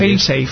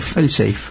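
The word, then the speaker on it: fail-safe: Please, file safe. – Uh-huh. – File safe, o cómo se pronuncia. – Fail safe. – Fail safe. Bueno, pues fail safe.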